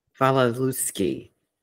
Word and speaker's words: Wasilewski: How do I say her last name? – Wasilewski.